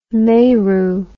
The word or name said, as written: Meru